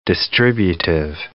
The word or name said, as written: distributive